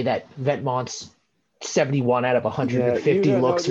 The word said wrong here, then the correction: Vetements: It's pronounced Vetements.